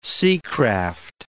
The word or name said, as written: seacraft